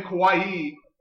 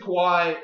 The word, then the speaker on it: Kawhi: By the way, you're pronouncing his name Kawhi. And I'm pronouncing Giannis. I think we're both fucking that up. – Could be. – I think it's Kawhi and Giannis, as you said.